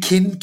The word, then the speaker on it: Kin: Kin, Keen? How do we pronounce it? – It's kin, yes. Like kin, like family.